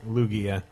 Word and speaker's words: Lugia: But anyway, Triskitable says Shadow Lugia. Is that how you pronounce it? Lugia? – Sure. – Lugia? – Lugia.